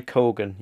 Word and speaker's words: Keoghan: But it's Barry Keoghan, yes. – Keoghan, right. – Keoghan, but, I think.